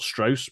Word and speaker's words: Strauss: Like the name is spelled Strauss, but he points out it's a southern pronunciation, Louis Strauss,